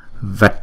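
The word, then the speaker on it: vaṭ: Chữ này, chữ T này có dấu nặng, chúng ta đọc là T, T. Uống cong lưỡi lại và búng ra T. Cho nên cái này chúng ta đọc là Vat, Vat.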